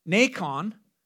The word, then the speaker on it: Nacon: And when they came to the threshing floor of Nacon, which happens to rhyme with bacon, when they came to the threshing floor of I can't even say it right.